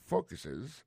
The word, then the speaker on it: focuses: Ça nous carré là, nous carré que, dans le mot focus là, accent tombé sur le premier syllabe là, this program focuses on requesting.